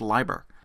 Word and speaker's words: Leiber: I know it's spelled Lieber, but it's pronounced Leiber.